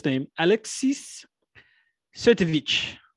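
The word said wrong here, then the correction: Sytsevich: Yeah, it's, yes, Sitsevich. Alexis Sitsevich, and he's not good in this.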